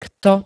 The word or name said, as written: kto